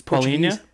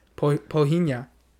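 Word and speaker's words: Palhinha: I think that's how you say his name. It's Polinha? Polinha.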